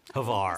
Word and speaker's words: Hvar: We went to Hvar. – Hvar. – That's how it's pronounced.